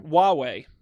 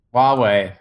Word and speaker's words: Huawei: Hawaii H U A W Huawei. – Huawei. That's right. That is how you say that.